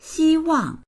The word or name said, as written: xi1wang4